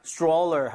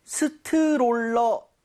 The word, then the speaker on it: stroller: 자, 근데 발음할 때 스트롤러 아, 이건 좀 아닌 것 같아요. – 아쉽네요. – 이 모양을 좀 많이 모아서 R 사운드 제대로 돼서 약간 치읓 비슷하죠? Stroller처럼 네. – 살짝 변하게 여러분들 이 모양 모으시고 혀 많이 당겨주세요.